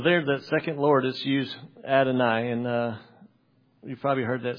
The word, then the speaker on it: Adonai: Some might say Adonai, but phonetically, you would say Adonai, which comes from the singular Adon.